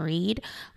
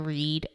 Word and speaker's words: Reid: It's The Sheena Reed. Follow me on Instagram as The Sheena Reed. Remember, my last name is spelled R-E-I-D. And follow me on Facebook. Facebook, I'm just up there as Sheena Reed. R-E-I-D is the last name.